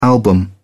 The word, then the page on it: album: British English